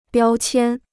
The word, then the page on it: 标签: biāo qiān